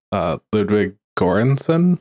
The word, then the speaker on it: Ludwig Göransson: I also want to point out that she worked with, and I'm probably going to mispronounce this name, Ludwig Göransson. – I think that is how you pronounce this.